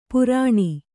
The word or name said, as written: ಪೈರಾಣ